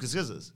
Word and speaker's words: Scorsese: How would you pronounce it? – Martin Scorsese's. – Well, that's the right way.